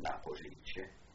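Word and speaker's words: Na Poříčí: It's in a street called Naporici, N-A, the second word, P-O-R-I-C-I, and the R is a little accent on it, and it's pronounced Naporici.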